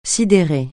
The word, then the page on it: sidérer: francais